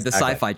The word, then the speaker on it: Syfy: Sci-fi channel, but it's spelled S-Y-F-Y.